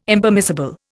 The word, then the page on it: Impermissible: Indian